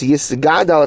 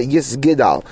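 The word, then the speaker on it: yisgadal (יִתְגַּדַּל): And again, the gimel, it's yisgadal, it's not yisgidal. One should also be careful to pronounce the word as yisgadal with the gimel as opposed to yisgidal.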